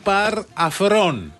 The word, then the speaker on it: Par Afron: Αλλά διαβάζεται Par Afron. Η επίσημη εκδοχή που άκουσα είναι Par Afron.